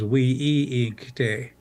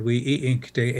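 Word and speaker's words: wíŋkte: A linguistic family on my reservation of Rosebud said that wasn't the original pronunciation of the word. The original word was Wi'i'ikte, a multisyllable word, Wi'i'ikte,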